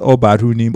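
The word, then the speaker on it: Ubadhunim: Yeah, so, and the way it's pronounced is Obarunim Olowolo.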